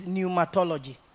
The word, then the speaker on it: pneumatology: The next thing Paul taught the church is pneumatology. Pneuma is spelled P-N-E-U-M-A-T-O-L-O-G-Y. Pneumatology. Pneuma and then tology. Pneuma is P-N-E-U-M-A and then tology, which simply means study.